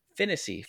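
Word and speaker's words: Phinisee: And they also get Rob Finissey. I emphasize the wrong syllable there. Finissey from Indiana.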